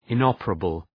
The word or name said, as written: inoperable